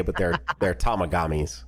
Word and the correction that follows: Tamagotchi: I believe is how you say it, right? – Yeah, Tamagotchi is correct.